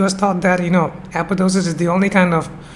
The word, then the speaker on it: apoptosis: So the spelling is A-P-O-P-T-O-T-O-S-I-S-I-S, but the second P is absent. So we don't pronounce it apoptosis, but apoptosis.